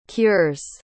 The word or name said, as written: cures